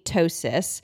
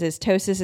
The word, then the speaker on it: ptosis: There's something called kidney ptosis.